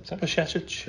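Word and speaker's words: Bajčetić: Pashetic. – There you go. That's how you pronounce it. – So just put a sh in the middle of it.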